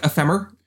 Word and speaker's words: Ephemer: I would guess it's just like Ephemer, like short for saying ephemeral. – Yeah, femur. Exactly.